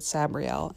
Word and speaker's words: Sabriel: So this book is by Garth Nix, and it's called Sabriel, or Sabriel, as I've always pronounced it, but he pronounces it Sabriel, and, you know, he made her up, so that counts.